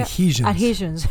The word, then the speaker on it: adhesions: I have additions. That's how you say adhesions. – It? – Adhesions. – Additions. – Additions. Like subtractions. – How do you say that? – Adhesions.